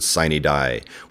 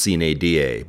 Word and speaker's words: sine die: For all of you homeschoolers out there taking Latin, I do know that it's actually pronounced sine die, but the legislature says sine die, and if there's one thing you learn at the legislature, it's that you don't mess with tradition.